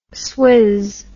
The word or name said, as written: swizz